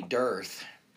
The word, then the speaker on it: Dirth: Named Tommy Dirth it's like it's spelled like Earth, but with a D in front of it.